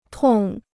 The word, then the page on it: 痛: tòng